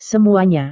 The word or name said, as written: semuanya